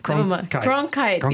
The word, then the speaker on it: Cronkite: Hello, it is I, Malaysia's Walter Conqueror. – Except you can't say it. – I can't say his name. Walter Cronkite.